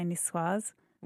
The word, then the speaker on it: niçoise: I think you can put the z on the end and say niçoise.